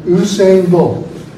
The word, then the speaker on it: Usain Bolt: Usain Bolt. Am I pronouncing his name correctly? – Excellent. – Usain Bolt.